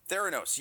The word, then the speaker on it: Theranos: Theranos.